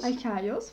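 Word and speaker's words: Archaios (ἀρχαῖος): Und zwar die Archäologie kommt aus dem Griechischen und besteht aus dem Wort Archios. Ich nehme an, so spricht man es aus. – Archaios, fast.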